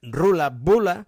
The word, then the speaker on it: Ruaille Buaille: El grup Ruaille Buaille, és a dir, pronunciat Rula Bula,